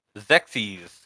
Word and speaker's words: Xexyz: X-E-X-Y-Z. – Phoenix? Zixi? – It's called Zexies.